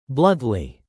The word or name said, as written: bluntly